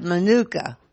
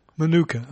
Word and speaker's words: Manuka: Well, you said hookah or nuka. I couldn't quite understand what you were rhyming with exactly. – Oh, well, we're going to have Manuka. – Manuka, okay.